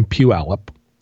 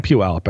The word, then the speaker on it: Puyallup: But then the thing was, after we were at the train show, which was down in Puyallup, which a lot of people that aren't from Washington don't know how to pronounce. – Mm-hmm. – We were down in Puyallup and we drove past one of those big, antique barns.